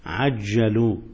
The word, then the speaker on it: عجلوا: ما ما أجلوا، قلبت المعنى تماما. ما عجلوا المغرب، أنت قلت ماذا قلت؟ أجلوا، أجلوا يعني أخروا، تبقى قلبت المعنى.